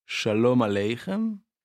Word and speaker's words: Shalom Aleichem: Donc là, ce qu'on vient d'écouter, c'était Shalom Aleichem. non, non pas Sholem Aleichem. Donc il y a la chanson et il y a l'écrivain. Il ne faut pas confondre les deux. Ça ne se prononce pas pareil.